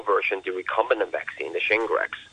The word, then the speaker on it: Shingrix: And there's the Shingrix, which is basically the recombinant vaccine.